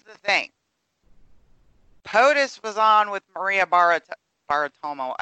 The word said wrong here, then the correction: Bartiromo: I always say her name wrong. I Bartiromo. – Bar Bartiromo.